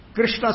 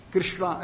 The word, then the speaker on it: कृष्ण: कृष्ण